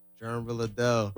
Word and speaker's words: Jerm Realadel: Jerm Realadel.